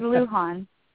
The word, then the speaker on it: Lujan: I don't think I pronounced your last name right, and I'm really, really sorry. How do you pronounce it? – It's Lujan. – Okay, I was close. – You were very close.